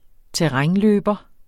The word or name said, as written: terrænløber